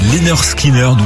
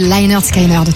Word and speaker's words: Lynyrd Skynyrd: Oui, parce que ça s'écrit avec un Y, mais sur leur premier album, l'album s'appelle Pronounced Lynyrd Skynyrd. – Ouais, c'est vrai. Donc sans doute c'est comme ça qu'il faut dire.